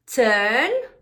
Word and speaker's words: turn: Palabras que se escriben con UR. Por ejemplo, turn, burn, urgent, turn, no turn, no turn, turn, turn, rima con bird, pájaro.